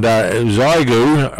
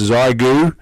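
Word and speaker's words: Xiegu: And Zygu, I presume, is correct pronunciation or thereabouts. It's spelt X-ray India Echo Golf Uniform. I think pronounced Zygu, and that's an X6100 he's looking for, HF 6 meter with tuner.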